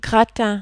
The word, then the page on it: gratin: French